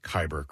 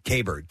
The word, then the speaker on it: Kyber: By the way, it's a Kyber crystal. – Kyber pass. – Yes. – Oh, not Kyber pass. No. Kyber crystal. – How did I pronounce it? Kaiber. K-A-I-B-U-R-R.